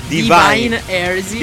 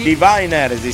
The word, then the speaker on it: Divine Heresy: Hai sentito, sì, che bella pronuncia anche qua. – Divine Heresy. Divine Heresy, sì, esatto.